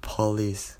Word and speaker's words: police: So if you want to say like the normal English pronunciation, it's gonna be like police. So it's not po, it's pa, police, police. So it sounds similar with please, P-L-E-A-S-E, please.